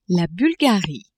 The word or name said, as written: Bulgarie